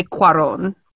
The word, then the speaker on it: Cuarón: Okay, I will tell you now that Cuarón. I'm looking at the spelling of it. It's definitely Cuarón.